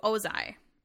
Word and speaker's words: Ozai: Azula thinks that the Fire Nation general messed everything up there, so she renames it to New Ozai.